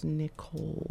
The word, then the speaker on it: Nicole: My name is Nicole. – Oh. – N-I-C-O-L-E. There's no H in there. The H is silent.